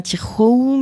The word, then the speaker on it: Tijoux: Ça se prononce Ana Tijoux, mais donc ça s'écrit avec un J. T I J O U X.